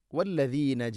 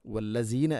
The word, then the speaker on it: والذين: വല്ലദീന ജഊ, വല്ലദീന, ഇവിടെ എല്ലാ ക്ലാസ്സിലും പറയാറുള്ളത് പോലെ തന്നെ ദാലിൻ്റെ ഉച്ചാരണം ശ്രദ്ധിക്കുക. – വല്ലസീന അല്ല, വല്ലദീന. വല്ലദീന ദാല് നാവിൻ്റെ അറ്റം മുൻപല്ലുകളുടെ ഇരുനരകൾക്കിടയിലൂടെ അല്പം പുറത്തോട്ട് വരണം, അതാണ് ദ.